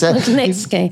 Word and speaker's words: Magnitsky: It's Magnitsky.